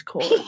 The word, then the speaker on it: Corps: Peace Corps. – It's like, corpse. I say the Peace Corps because that's how I first heard it heard it. – Like dead body? – Corp. But it's spelled C-O-R-P-S.